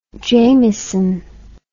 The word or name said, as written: jamieson